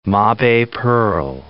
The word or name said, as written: mabe pearl